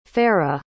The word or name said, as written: farah